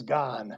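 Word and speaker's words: Gane: I've heard it pronounced gone. Everybody pronounces it different, but I believe by following most of the riders and such that it is gone.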